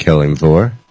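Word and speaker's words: Kelimvor: And I didn't say it right, did I? – You said it right. Yeah, I believe you said Kelimvjor.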